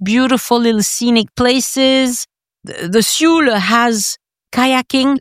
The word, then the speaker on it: Sioule: You have the Sioule Valley, which I had never heard of either. I can see your face. You haven't heard of it. – I don't even know how you spell that. – S-I-O-U-L-E.